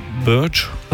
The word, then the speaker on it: Birge: Wie spricht man das aus? Birge geschrieben. – Ja, Birch.